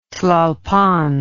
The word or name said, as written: Tlalpan